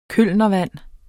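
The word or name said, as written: KØLNERVAND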